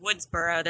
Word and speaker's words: Woodsboro: So I know that there's a ghost mask, it might take place in Woodboro, and people are going to die in the movie. – Okay, first of all, it's pronounced Woodsboro, there's an S.